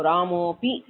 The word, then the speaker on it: रामोऽपि: किन्तु तत्र अकारः नास्ति उच्चारणमपि न करणीयः रामोऽपि इत्येव एवं लेखने अपि दोषः नास्ति सामान्यतः अवग्रहः लिख्यते किन्तु यत्र यत्र अवग्रहः तत्र पूर्वरूपम् इत्यपि नास्ति तद् लेखनीयम् इत्यपि नास्ति अकारः तु नास्त्येव अकारस्य स्थाने ओकारः आदेशः कृतः सो रामोऽपि इत्येव उच्चारणम् ओकारः पकारः तावदेव अस्ति